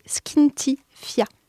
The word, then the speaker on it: Skinty Fia: Et le premier mot, c'est Skinty Fia. Skinty Fia.